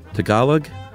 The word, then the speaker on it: Tagalog: Once again, it's Tagalog. – Tagalog. – Tagalog? – Tagalog. I think. – Well, this is G-A-H, not G-U-H.